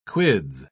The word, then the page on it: quids: British English